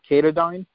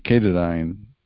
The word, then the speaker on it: Katadyn: Is it called Katadyn? – Yeah, it's spelled with a K. I think it's K-A-T-Y-N-E, Katadyn.